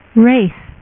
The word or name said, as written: blood wraith